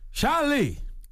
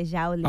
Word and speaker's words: Xiaoli: Xiaoli. – It's spelled X-I-A-O-L-I. – Okay, Xiaoli.